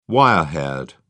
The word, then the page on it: wire haired: British English